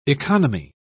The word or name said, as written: economy